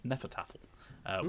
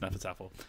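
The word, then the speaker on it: Hnefatafl: But it's Hnefataffel. So when I got the box and I tried to read it, it was a struggle. But apparently it's pronounced Nefertaffel.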